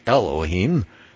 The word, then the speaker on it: Elohim: Then Hebrew 430 is Elohim, or gods, Strong's number 430, pronounced Elohim, the plural of 433, which means rulers, judges, sublime ones, or there it says divine ones, messengers, and they say angels.